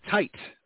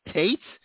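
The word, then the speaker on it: Tait: Happy birthday as well to Alex Datcher, Ensign Tight. I believe it's Tight Tate.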